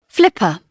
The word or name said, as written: flip